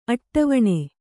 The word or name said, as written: ಅಟ್ಟವಣೆ